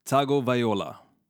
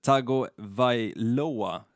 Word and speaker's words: Tagovailoa: Och sen såklart, Tua Tagovailoa. Bra Olsson. Quarterback i Miami Dolphins. Jag tror att jag fick fel lite på uttalet där på slutet. Tago Vailoa ska det vara, inte Loa.